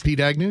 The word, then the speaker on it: Pete Agnew: Dan McCafferty, Manny Charlton, Pete Agnew. Am I saying that right? – Pete Agnew, yep.